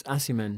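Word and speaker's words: Aciman: Am I pronouncing? – I think it's Assiman.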